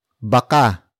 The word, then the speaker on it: baka: For example, baka manilawang kubiertos. Baka manilawang kubiertos. Baka manilawang kubiertos. It means, the utensils might become yellowish. Word for word it's, baka, manilaw, ang, kubiertos.